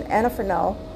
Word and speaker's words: Anafranil: Now, I usually wouldn't do this, I would never do this about a medication, but this medication, Anafranil, that's how you say it.